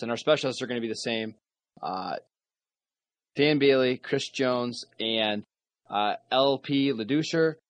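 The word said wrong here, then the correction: LP Ladouceur: Lattisseur, no, for God's sakes. LP Lattisseur.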